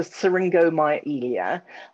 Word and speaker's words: syringomyelia: Okay, I think you're probably referring to syringomyelia and the related condition, Chiari malformation.